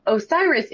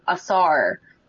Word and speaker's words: Osiris: Osiris isn't the original pronunciation. Speaking of pronunciations, that was the Greeks westernizing him. The original pronunciations were believed to be closer to Asar, or Asar, or Osir, or Wisar. Usar or Usire.